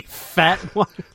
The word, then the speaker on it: Fatone: Joey Fatone. – That's his name, isn't it? F-A-T-O-N-E. Isn't that how you say it? – Yeah, he buys his clothes at Versace.